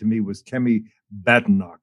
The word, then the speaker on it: Badenoch: Badenoch, right.